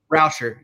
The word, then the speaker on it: Rauscher: How you pronounce his last name? – Rauscher, yeah.